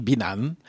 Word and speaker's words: Binan: In Australia, where I was, an English-speaking country, they can pronounce Binan, and it's not a problem for me. In Turkey, because Turkey has a very easy language, they made the right pronunciation, Binan. It's Binan in French.